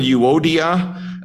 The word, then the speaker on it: Euodia: So the first one, Euodia, and the second one, Syntyche. As far as I can tell, that is the way they are to be pronounced. I don't speak Greek, but that's the way it would have been. But there was a disunity between these two sisters.